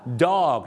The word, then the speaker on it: dog: dog ต้องออกเสียงดีๆนะครับ dog นะครับไม่ใช่ dog นะฮะถ้าออกเสียงว่า dog เนี่ยนะฮะจะแปลว่าเอาเรือนะครับไปเทียบท่าไปจอดเรือนั่นเองครับ dock the boat นะฮะต้องออกเสียงว่า dog